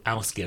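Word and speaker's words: Ásgeir: And for those that wonder about pronunciation, I looked it up and then saw it's called Ausgier.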